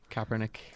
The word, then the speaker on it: Kaepernick: Kaepernick.